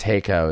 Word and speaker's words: Hakko: I think it's Hako is the way that you pronounce it, but Hakko. And they have like a $100 iron.